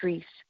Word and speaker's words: Shrese: My first name is Shrese. Shrese. – Shrese? – But Shrese is one syllable. It's kind of like saying Shrek with an S. Instead of saying Shrek, say Shrese.